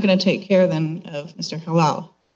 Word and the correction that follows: Chahal: Did I say it right? Chahal? – Chahal, excuse me.